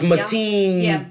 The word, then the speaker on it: Mateen: It's Mateen.